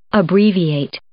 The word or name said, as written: abbreviate